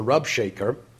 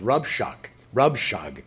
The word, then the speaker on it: Rabshakeh: One was three syllables, the Rabshakeh, and one was two syllables, Rabshag.